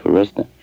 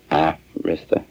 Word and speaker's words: Arista: Or as Clive would have it, Arista. – Oh yeah, there's a big controversy over that. – Yeah. – How do you say the name of the company? – Well, if you're around Clive, you say Arista.